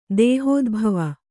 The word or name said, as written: ದೇಹೋದ್ಭವ